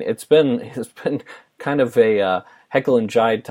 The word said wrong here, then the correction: Jekyll and Hyde: type of team. Am I saying that right? I feel like I'm saying that wrong. – Jekyll and Hyde.